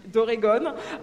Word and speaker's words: Oregon: c'est comme ça qu'on prononce, d'Oregon,